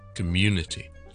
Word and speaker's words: community: 由于第一个 syllable 系唔系重音嘅关系，所以我哋读成系 com com com，就唔使读得咁大声 com 咁咧就太夸张啦，com 就得啦。跟住后面重音 munity community community community community center。